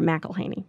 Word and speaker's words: McElhaney: Spelling's not my forte, and I married a guy named McElhaney. It's M-C, capital E-L-H-A-N-E-Y, Myra McElhaney. – And Myra is M-Y-R-A, so Myra, yes.